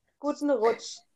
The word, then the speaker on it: Guten Rutsch: Guten Rutsch? – Guten Rutsch.